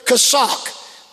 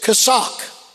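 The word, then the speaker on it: chazak (חזק): The word be strong is the Hebrew word chazak. That's C-H-A-Z-A-Q. C-H is pronounced like a K, and the Q is pronounced like a K in Hebrew to English. Translating and it says chazak. Be strong and of good courage. Of good courage. Courage is the word amats. A-M-A-T-S.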